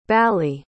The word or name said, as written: bally